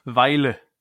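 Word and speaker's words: Vejle: Ein alter Bekannter, Alexander Brunst von Velje aus äh, Dänemark. Oder wie spricht man richtig aus? Weile. Weile, aus Weile äh, ist gekommen.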